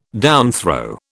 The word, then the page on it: downthrow: British English